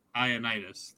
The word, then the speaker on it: Ioannidis: Ioannidis.